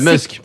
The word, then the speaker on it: Musk: Enfin, je ne sais pas si je le prononce bien. – Ouais, Musk. – On dit Musk. – C'est quand même Musk.